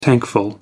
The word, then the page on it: tankful: American English